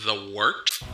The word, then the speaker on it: Wort: It is The Wort, not warts, not forts, not short, The Wort.